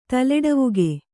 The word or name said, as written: ತಲೆಡವುಗೆ